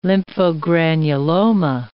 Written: lymphogranuloma

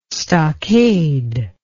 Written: stockade

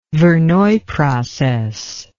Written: verneuil process

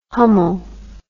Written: hummel

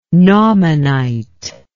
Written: naumannite

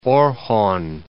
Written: Orhon

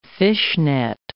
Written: fishnet

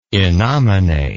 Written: innomine

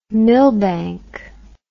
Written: millbank

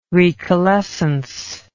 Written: recalescence

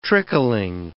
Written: trickling

